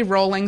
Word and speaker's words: Rowling: Rowling signs book after book. I know her name is pronounced Rowling, like Rowling on Molly, and I know the K was added to her pen name to make her seem more legit.